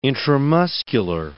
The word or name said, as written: intramuscular